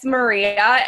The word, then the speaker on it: Maria: Sorry, I'm just gonna make a small correction. – Did I say your name wrong? – It's Maria.